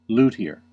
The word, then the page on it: luthier: American English